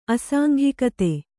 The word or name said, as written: ಅಸಾಂಘಿಕತೆ